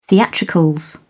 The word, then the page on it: theatricals: British English